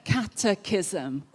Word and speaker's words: Catechism: I can never pronounce this word. The Westminster Catechism. Woo, that's the first. I got it right.